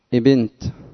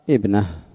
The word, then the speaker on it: ابنة: قال ابن منظور رحمه الله تعالى قال: ويقولون ابنت بكسر الباء مع همزة الوصل، وهو من أقبح الأوهام وأفحش اللحن، لأن همزة الوصل لا تدخل على متحرك، وإنما جتنبت للساكن ليتوصل بإدخالها عليه إلى افتتاح النطق به. والصواب أن يقال فيها ابنة أو بنت.